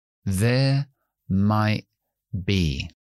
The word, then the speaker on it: might be: La T no la pronunciamos porque tenemos después una consonante bilabial, que es be. Entonces decimos there might be.